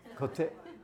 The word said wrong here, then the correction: קוצר: how you say it? Kotzer, that's the word.